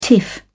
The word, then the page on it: tiff: British English